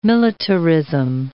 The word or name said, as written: militarism